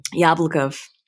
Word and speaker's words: Jablokov: Jablokov.